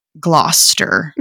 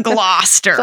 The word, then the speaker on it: Gloucester: Several other towns were involved in the witch trials, including Andover, Ipswich, Haverhill, Topsfield, and Gloucester, right? That's how you pronounce it. – You did it. – Gloucester.